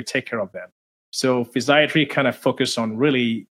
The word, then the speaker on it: physiatry: Yeah, so physiatry, you pronounce it correctly, is the same as physical medicine and rehabilitation.